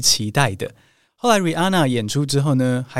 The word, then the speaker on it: Rihanna: 蕾哈娜的英文不是念作 Rihanna 哦，那个 H 其实不发音，应该要念作 Rihanna。